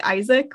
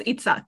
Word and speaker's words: Yitzhak: Because I always go to like Isaac, which is the English like pronunciation, or Yitzhak, which is like the Hebrew pronunciation.